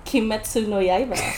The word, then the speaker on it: Kimetsu no Yaiba: Kimetsu no Yaiba.